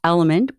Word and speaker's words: LMNT: This episode is brought to you by Element, pronounced Element, but spelled L-M-N-T.